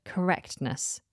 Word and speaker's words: correctness: If you look in the dictionary, you'll also see the T is written in the phonetic transcription. But then when you listen to the samples, they don't pronounce it either. It's quite difficult to pronounce a T straight after a K. Correctness, correctness, correctness. It's too much. So don't worry about that T.